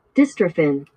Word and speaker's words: dystrophin: Let's see how this word's pronounced. Dystrophin. Dystrophin. Sounds scary. – Dystrophin. – Oh, you're getting it right. Dystrophin. Hey, look at me getting big words right.